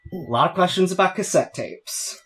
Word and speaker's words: cassette: You know, when I was taking French in high school, I found out that in French, they would say, they would advertise cassette using the letter and number K and 7, because in French you pronounce that cassette.